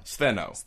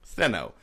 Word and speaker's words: Stheno: That's a lot of S-T-H-E-N-O. – S-T-H-E-N-O? – Steno. Yeah. – Steno.